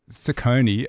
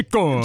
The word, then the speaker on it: Ciccone: I reckon I've anglicised the pronunciation. And it's because I've said Ciccone as opposed to Ciccone. – Ciccone. – Ciccone. Madonna Ciccone.